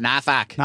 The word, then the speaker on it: Norfolk: You got to say it like that. Norfolk.